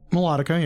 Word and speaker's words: Melodica: Other than that, I got a Honer Melodica, or whatever the crap you pronounce it, so... – Melodica, yeah.